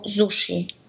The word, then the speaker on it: zushi: Jak zauważyliście, że gdy wraz z innym wyrazem mm, łączymy wyraz sushi, to ten drugi człon wymawiamy właśnie jako zushi. I wynika to po prostu z japońskiej wymowy.